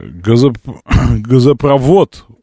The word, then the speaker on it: газопровод: Извините, газопровод, ударение на вод.